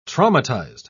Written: traumatized

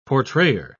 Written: portrayer

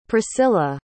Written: pricilla

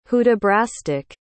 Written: hudibrastic

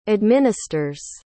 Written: administers

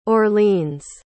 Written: orleans

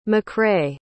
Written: MacRay

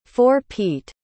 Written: four-peat

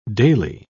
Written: daily

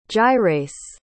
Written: gyrase

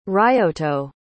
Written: ryoto

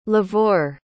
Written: livor

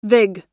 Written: vig

